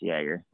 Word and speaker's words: Jagr: It doesn't really matter, but it is pronounced Yager.